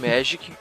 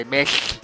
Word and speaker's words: Magic: Eu, go eu não gosto de Magic. Desculpa, é, eu pronunciei errado. É Magic.